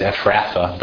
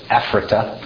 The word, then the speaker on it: Ephrata: But people started pronouncing the TH as th instead of the hard T. So that's how it becomes Ephratha, but Ephrata. So, and you, Bethlehem, Ephrata, or however the English works it, Ephrata.